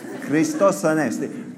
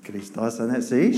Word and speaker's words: Christos Anesti: Now say it with a Greek accent. Christos Anesti. Tell us. Christos Anesti. See, she said it right.